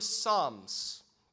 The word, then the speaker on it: Psalms: The book of Psalms, you know, the word Psalms, in Greek, it's the word psalmos, psalmos. By the way, it's pronounced psalms, right? The P is silent.